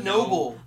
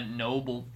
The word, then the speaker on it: Nobel: She called it a noble. – A Nobel Peace Prize.